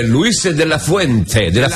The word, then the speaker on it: Luis de la Fuente: Devo fare i complimenti al mister della nazionale spagnola, però Luis de la Fuente.